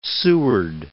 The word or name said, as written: Seward